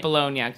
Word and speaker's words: Bologna: But I think he pronounced it Bologna to rhyme with Sharona.